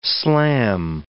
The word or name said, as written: slam